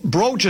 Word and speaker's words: brooch: Here someone says, isn't it pronounced brooch like roach? No, brooch is like if you broach the subject.